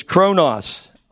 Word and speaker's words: chronos: So the last word, and the word that I mispronounced last week, was chronos.